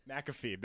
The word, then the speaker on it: McAfee: McAfee. That's how you say his name.